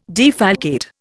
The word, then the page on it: defalcate: Indian